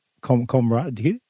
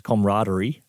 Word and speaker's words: camaraderie: Comrade? – Yeah. – Really? – I'm pretty sure the word's pronounced camaraderie. Camaraderie, and he always used to call it camaraderie.